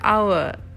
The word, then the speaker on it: our: Jadi uh, seems like kedengerannya itu kayak kita bilangnya jam, hour. Sebenarnya bukan. Bacanya gimana polisi kata? R. R. Jadi kayak tul kita baca tulisan R ya. A R A, A R E. Nah, kayak kita baca r R.